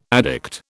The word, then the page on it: addict: British English